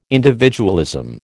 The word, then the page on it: individualism: American English